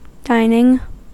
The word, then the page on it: dining: American English